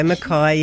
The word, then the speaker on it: Mackay: Oh, in Mackay. – Queensland. There you go. Mackay.